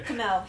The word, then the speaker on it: Kumail: Am I saying that right? – Yeah, sure. – Okay. – Kumail. He pronounces like Kumail.